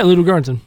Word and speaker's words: Ludwig Göransson: but is it Ludwig Göransson? – Yeah, Ludwig Göransson.